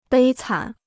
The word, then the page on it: 悲惨: bēi cǎn